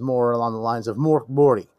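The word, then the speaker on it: Mörk Borg: So we'll call it Morkborg because a lot of people know, but Moriborty, if I'm pronouncing that right, I may still be butchering it. The G is supposed to be more of a Y.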